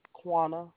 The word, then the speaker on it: Quanna: Quana. – Could you spell it? – Q-U-A-N-N-A. – Q-U-A-N-N-A.